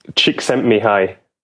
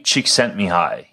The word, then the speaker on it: Csikszentmihalyi: Csikszentmihalyi. – Mihaly Csikszentmihalyi.